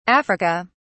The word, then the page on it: Africa: British English